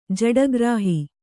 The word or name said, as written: ಜಡಗ್ರಾಹಿ